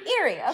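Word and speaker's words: Erie: It feels set up for Ire? How'd they pronounce it? Irie? – Irie? Irie, okay. – Sure. – Yeah. – Let's go with Irie.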